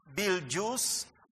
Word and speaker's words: Betelgeuse: Now, this word is BETELGEUSE. But how is it pronounced? B-E-T, like bete, B-E-T-E. L-G-E-U-S-E, but how it's pronounced is Beljuice or Beljuice. In other words, don't try to pronounce Betelgeuse.